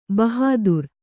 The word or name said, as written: ಬಹಾದುರ್